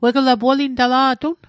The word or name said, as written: wegla